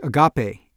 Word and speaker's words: agape: One was a Greek word that Martin Luther King was very fond of, a word that means selfless love, and that word is agape, A-G-A-P-E. Looks like agape. And so I knew it wasn't pronounced agape, but to say agape made it sound sort of vaguely Latin American or Spanish or Portuguese or something, and that didn't seem quite right either.